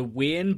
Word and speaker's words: Weirn: How do you pronounce that, JB? – I think you said it right. – Weirn. Weirn. We, yeah, yeah, just weird, but with an N.